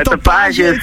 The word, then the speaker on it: tapage: Tapage is ook een vrij groot misverstand over hoe het uitgesproken moet worden. Het is een Frans woord, dus je spreekt het uit als tapage.